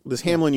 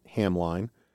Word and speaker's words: Hamline: David French, who recently quasi-departed David French, got into trouble for pronouncing it Hamline. Which is how it's spelled. – It's how it's spelled. So if you're trying to look it up, it's spelled Hamline, but I'm told that it's pronounced Hamlin.